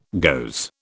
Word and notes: goes: British English

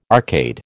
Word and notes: arcade: American English

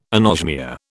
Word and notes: anosmia: British English

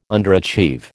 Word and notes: underachieve: American English

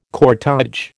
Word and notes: cortège: American English

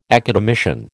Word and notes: academician: American English

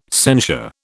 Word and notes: censure: British English

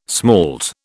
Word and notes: smalt: British English

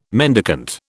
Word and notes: mendicant: British English

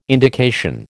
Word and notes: indication: American English

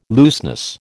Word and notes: looseness: American English

